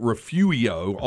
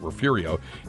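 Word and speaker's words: Refugio: But some I said Refurio, and then somebody went Refuio on the Specs text line and said that, of course. And the texter was correct. Was the original Spanish pronunciation, but everybody calls it Refurio.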